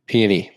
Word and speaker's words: Peony: I think it's peony. Peony? I don't know. – Peony. – Peony. He told me how to pronounce it. It's P-E-O-N-Y, Lane.